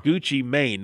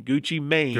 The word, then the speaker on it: Gucci Mane: Rapper Gucci Mane. Did I say that right? – Correct, yeah. – Gucci Mane. Not man, Gucci Mane.